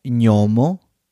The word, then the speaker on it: gnomo: So GN is pronounced ñ. Examples. Lo gnomo, the gnome.